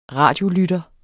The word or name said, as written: radiolytter